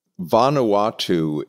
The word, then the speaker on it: Vanuatu: Vanuatu is the name of the country.